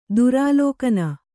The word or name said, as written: ದುರಾಲೋಕನ